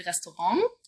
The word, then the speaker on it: Restaurant: Zum Beispiel Restaurant, also mit NG hinten.